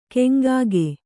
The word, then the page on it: ಕೊಂಕಿಗ: koŋkiga; kannada